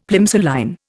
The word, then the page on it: Plimsoll line: Indian